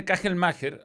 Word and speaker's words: Kagelmacher: él dijo que se dice Kagelmacher, así que ahora vamos a decirle como él dice que se dice.